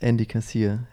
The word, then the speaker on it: Andy Kassier: Es ist Andy Kassier. Andy als englischer Vorname und Kassier kommt eigentlich von den Hugenotten und war mal Kassier,